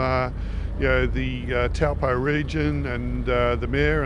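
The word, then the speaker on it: Taupō: we will be racing in Taupo, or the correct pronunciation, Topua. yeah.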